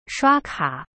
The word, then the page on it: 刷卡: shua ka